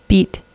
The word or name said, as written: beet